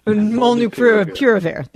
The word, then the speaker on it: molnupiravir: Yesterday, an FDA advisory panel recommended, I'm probably going to butcher this, Molnupiravir. – Molnupiravir. – There you go.